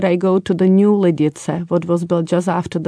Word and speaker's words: Lidice: So we pronounce it as Lidice. – Lidice. – Mm-hmm. – Lidice, because there is the ts sound.